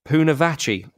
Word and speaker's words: Pounewatchy: Now, where's the, the Pounewatchy, that's how you pronounce it.